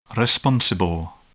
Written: responsible

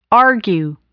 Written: argue

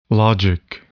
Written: logic

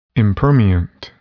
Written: impermeant

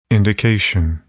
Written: indication

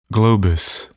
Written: globus

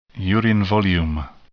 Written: urine volume

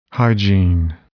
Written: hygiene

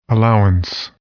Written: allowance

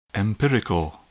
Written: empirical

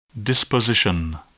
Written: disposition

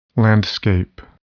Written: landscape

